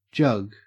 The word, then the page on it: jug: Australian English